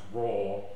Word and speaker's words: AWR: And then from that, pronounced raw, which is raw, A-W-R, raw code essentially.